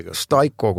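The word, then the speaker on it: Steico: Steiko on õige hääldus, kui Steiko, ma eksi. Ja. Staiko, kui me arvestame, et on Saksa pärane hääldus õige, puidupõhised materjalid, mis need on? – Kõik, mis on võimalik teha puidust.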